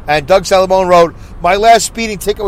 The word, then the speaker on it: Salamone: Doug Salamone, by the way, S-A-L-A-M-O-N-E.